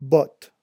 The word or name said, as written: botte